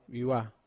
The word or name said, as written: vawa